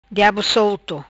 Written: Diabo solto

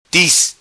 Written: Tiz

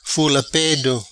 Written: Fula Pedo

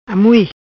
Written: Amui

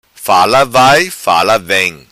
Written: vai fala vem

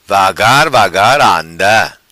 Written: vagar vagar anda

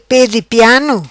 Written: Pe Di Piano

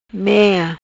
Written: Mea

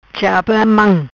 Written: Chapa Mam

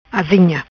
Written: Azinha

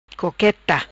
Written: Coqueta

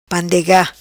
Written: Pandega

